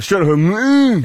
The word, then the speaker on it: Strait of Hormuz: Say it correctly. – Then this is the maturity we're after. Strait of Hormuz.